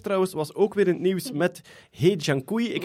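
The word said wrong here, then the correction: He Jiankui: Eh, vol, als ik het juist gelezen heb in zijn sms, zou de correcte uitspraak Hu Junkwei zijn. nee, ge was het een hele zachte G. Ge Junkwei.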